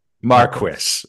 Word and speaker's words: Marquis: Marquis.